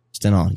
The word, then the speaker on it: Stendhal: Am I pronouncing that correct? – Stendhal syndrome. – Stendhal, yes.